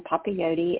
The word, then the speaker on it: Papayoti: Instagram, Twitter, it'll be under my name, Ann Papayoti. And you did say it right, Sarah.